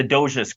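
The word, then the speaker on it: Doğuş: So to sum it up, there's a company called the Doges Group. It sounds spelled like Dogus, D-O-G-U-S. And they are a conglomerate in Turkey that is comprised of approximately 250 companies in 7 industries, which includes tourism.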